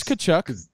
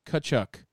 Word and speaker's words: Tkachuk: First of all, I think we were saying his name wrong. – No, it's Tkachuk. – Tkachuk? Okay. – No, it's T. – He played. – No T. Tkachuk. Confirmed by our hockey insider.